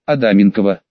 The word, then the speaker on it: Адаменкова: Девушка за регистрационной стойкой, глядя на транскрипцию на английском, спросила Адаменкова: «Всегда, получив документы, кто-то пытался сделать ударение на втором слоге. Адаменкова». Маришка не выдерживала и всегда поправляла: «Адаменкова. Вот так, Адаменкова».